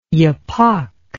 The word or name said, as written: yapok